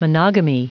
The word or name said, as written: monogamy